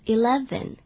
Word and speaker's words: eleven: 再来说一遍 eleven，而且要小心那个 v 不要念成 w，不是 eleven。